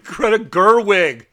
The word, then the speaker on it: Greta Gerwig: Greta Gerwig.